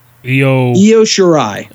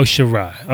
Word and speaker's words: Shirai: Io Shirai. – Oh, Shirai.